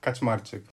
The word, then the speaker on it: Kaczmarczyk: Okay, so my full name is Jakub Kaczmarczyk.